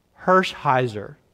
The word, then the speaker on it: Hershiser: Did I say it right? Oral, say it for me. Hershiser.